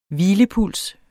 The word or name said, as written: hvilepuls